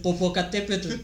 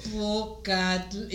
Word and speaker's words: Popocatépetl: Popocatépetl. La primera. Popocatl.